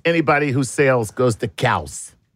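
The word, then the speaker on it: Cowes: Cows. – Cows. It's spelt with an E.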